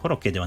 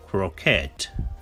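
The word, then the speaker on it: croquette: さあ、こちらは英語では、えー、コロッケではなくて、クロケット。ちょっと発音が違いますね。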